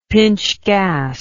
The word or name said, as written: pintsch gas